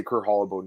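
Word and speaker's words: Kurt Holobaugh: So I've been saying Kurt Holobo for 10 years. I'm going to say Kurt Holobo now because that's how he pronounces it. However the fighter pronounces it is how I'm going to pronounce it.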